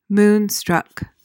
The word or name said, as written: moonstruck